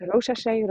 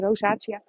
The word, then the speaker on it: rosacea: ik weet niet hoe ik het uitspreek, rosacea, zo spreek je het uit, rosacea, rosacea, zoiets.